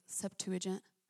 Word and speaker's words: Septuagint: Guys, like, even the law that was given to Moses, literally, in, like, the Greek Septuagint, that's how you say it, thank you.